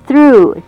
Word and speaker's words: through: 啄木鸟，这种鸟不太常见哈。Goes out through the other through T H R O U G H，最后的 G H 在这边是 silent word 不发音。Through